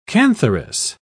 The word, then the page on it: cantharis: British English